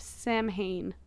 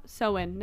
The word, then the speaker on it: Samhain: Ancient origins of Halloween date back to the ancient Celtic festival of Samhain. Oh, Samhain. Never mind. Pronounced it wrong.